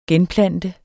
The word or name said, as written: genplante